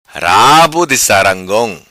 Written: Sarangong Rabo Di

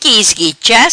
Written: Esquicha

